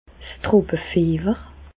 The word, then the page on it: d' Tropefiewer: Bas Rhin; Reichshoffen